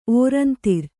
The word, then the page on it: ಓರಂತರ: ōrantara